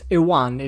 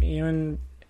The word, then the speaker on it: Iwan: No, it's Ewan. It looks the name. It's spelled I-W-A-N, but I feel like it might be Ewan. It says pronunciation Ewan.